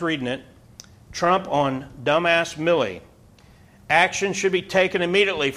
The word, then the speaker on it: Milley: Is his name pronounced Miley or Millie? – Millie Vanilli. – That's how. Yeah, that's how you remember.